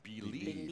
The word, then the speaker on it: Bleab: So it's spelled B-L-E-A-B. – Right? Yes. – But it's pronounced Believe.